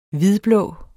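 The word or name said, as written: HVIDBLÅ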